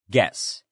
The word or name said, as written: guess